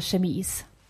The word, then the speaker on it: chemise: La Chemies. – Nej. – Skjorta på franska heter chemise.